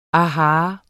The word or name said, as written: aha